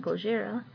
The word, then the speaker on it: Gojira: Godzilla, often called by the Japanese pronunciation Gojira, first came to the big screen in black and white in 1954, and it was put out by Toho Studios.